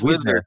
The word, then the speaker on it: Wisner: Wisner, I thought it's pronounced. Is it Wisner? – I think it's Wisner. W-I-S-N-E-R. – It's not W-I-E-S? – I'm pretty sure it's just W-I, I think.